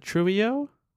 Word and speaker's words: Trujillo: How do you spell it? – T-R-U-J-I-L-L-O. – Is it Trullio? – Yeah.